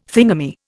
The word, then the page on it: thingummy: Indian